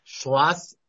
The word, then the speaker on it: Schloss: Schloss, S as in Sam, C-H-L-O-S-S. – S-C-H-L-O-S-S.